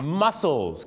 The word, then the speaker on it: mussels: mussels ครับที่แปลว่ากล้ามเนื้อเหรอครับที่จริงออกเสียงเหมือนกันแต่สะกดกันไม่เหมือนกันนะครับ